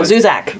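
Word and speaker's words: Zusak: How do you say his last name? Zuzar. – Zuzar. – Well, no, Zuzak.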